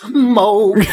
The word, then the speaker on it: Moog: it's pronounced Moog.